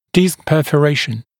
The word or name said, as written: disc perforation